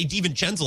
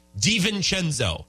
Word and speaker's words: DiVincenzo: By the way, no one outside of Bucks fandom can say his name. Like, Reggie Miller. Poor guy. And then I was listening to Bill Simmons' pod today. They're like, DiVincenzo? It's a V. It's not hard. Like, DiVincenzo. Could not be easier, guys.